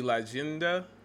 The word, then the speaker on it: Leyenda: Because one thing I do know, that Y is pronounced like a J. So it'll be Leyenda del Milagro Tequila.